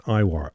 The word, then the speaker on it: Eihwar: Iwar, let's spell it again, E-I-H-W-A-R.